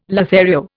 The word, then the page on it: Lothario: Indian